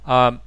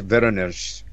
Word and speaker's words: Voronezh: Voronezh, is that how you pronounce that? And I should know. – I believe it's Voronezh.